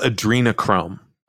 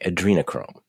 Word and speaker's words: adrenochrome: How do you pronounce it? – It's called adrenochrome. – Okay, adrenochrome.